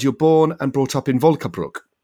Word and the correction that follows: Vöcklabruck: Vöcklabruck. Vöcklabruck, yeah. Vöcklabruck, yeah. Th- thank you.